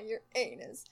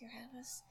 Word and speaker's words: Uranus: After Saturn, we get to Uranus, which is the correct pronunciation, because everybody decided to go with, your anus. Guess where I'd like to go in the entire galaxy? Your anus. So, like, human immaturity, as usually, ruined a perfectly good pronunciation, but it is funny, I have to admit.